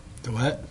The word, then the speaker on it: Dalet: Then you get into verses 25 through 32, D-A-L-E-T. You want to give a shot? How do you say that? – Dalet.